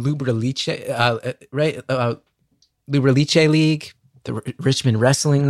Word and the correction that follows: Lucha Libre: Lucha Libre. Lucha Libre. I was mispronouncing that.